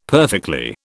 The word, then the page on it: perfectly: British English